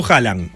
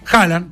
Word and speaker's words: Haaland: ¿Cómo lo pronunciás vos? – Yo digo Haaland. – Haaland, perfecto. Haaland.